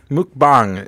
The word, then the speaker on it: mukbang: Mukbang is how I'd want to pronounce that. – Yeah, and I think that's what I said bang. The first time, wasn't it? Or I said muk. – You said muk, yeah. – I did say muk, you're right. – But you got the latter half right.